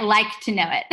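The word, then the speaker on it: LIKEtoKNOW.it: If you don't mind sharing, I see that you're always tagging Like It to Know It. Did I say that right? – Yeah, Like to Know It. – Like to Know It. I always add the it.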